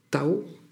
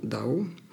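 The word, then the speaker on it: Dao (道): Ce taoïsme place le respect de la vénération du Tao, qu'on appelle maintenant Dao, qu'on prononce maintenant Dao, et qui est conçu comme une réalité ultime, immanente et transcendante au cœur de sa doctrine.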